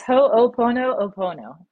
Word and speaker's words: ho'oponopono: Yeah, it's ho'oponopono.